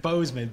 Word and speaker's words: Boozman: That or I think he's Boozman, but it's spelled B-O-O-Z-M-A-N.